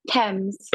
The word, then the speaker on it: Thames: Am I saying that right? Thames, yeah. – Thames. – Oh, Thames. I'm sorry, Thames. Thames I knew that.